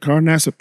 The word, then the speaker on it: Carl Nassib: Carl Nassib.